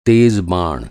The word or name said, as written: बाण